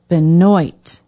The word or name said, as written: benoit